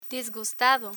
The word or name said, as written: disgustado